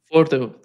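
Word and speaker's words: Fordow: because to attack Fordo, is that how you pronounce it, Fordo? – Fordo, that's right, yeah.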